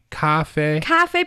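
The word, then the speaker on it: 咖啡: It's the tones. – Yeah. – It's a little higher, right? – Yes. – Cafe, cafe. – Mm-hmm. – And it's the same tone for the first syllable and the second syllable.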